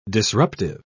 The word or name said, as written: disruptive